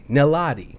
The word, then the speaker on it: Niladi: Even Lottie's name is N-I-L-A-D-I. Nilati.